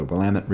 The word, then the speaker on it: Willamette: It takes place in downtown Portland and the banks of the Willamette River and Waterfront Park. – Rhymes with damn it. – If nothing else, this pod will promote the proper pronunciation of Willamette.